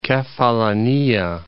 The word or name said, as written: Kefallinia